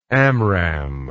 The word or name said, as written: Amram